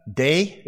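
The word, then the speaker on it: day (δή): Delta eta, day, it's pronounced.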